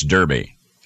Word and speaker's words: Derby: But the man who won was Edward the 12th Earl of Derby, which most of us U.S. pronounce Derby.